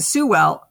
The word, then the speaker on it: Sewell: Sewell iced the game for Detroit.